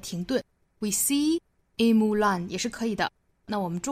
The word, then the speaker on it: Mulan: 在英语里呢，单词是会有重读音节，会有弱读音节的，那这个单词是体现在后面重读 Mulan da da。